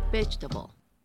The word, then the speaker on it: vegetable: La manera correcta de decirla es vegetable, sin pronunciar la segunda E.